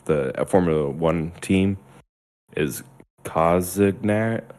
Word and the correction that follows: Cognizant: or something like that. It's COZ. I don't know if I'm saying it right. Yeah. Cognizant. – Cognizant.